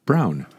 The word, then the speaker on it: Braun: His name looks like Braun, B-R-A-U-N, and it's pronounced Brown.